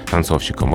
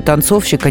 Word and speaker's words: танцовщик: Но здесь еще на всякий случай, что танцовщик, а не танцовщик. Просто, да, ну, да, да, бывает с ударением такое. на «о». И танцовщица, да, тоже с ударением на «о».